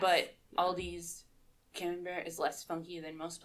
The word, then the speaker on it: camembert: I pronounce it camembert. That's how I've heard it.